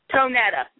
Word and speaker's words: Tonetta: How do you say your name? It's very unusual. Tonetta? – Tonetta, yes.